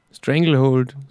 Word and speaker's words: Stranglehold: Ted Nugent mit, wie spricht man das aus? Stranglehold. Ted Nugent. fiedelt er sie am Nummer 1 am Schluss. Ha! Stranglehold, so spricht man es aus.